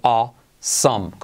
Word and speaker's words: awesome: Con esa vocal awesome. Como asómate. Awesome.